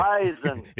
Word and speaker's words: Bison: Bison. – It may be spelled B-I-S-O-N, but it's pronounced B-I-Z-O-N.